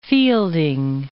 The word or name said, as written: Fielding